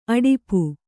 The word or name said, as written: ಅಡಪು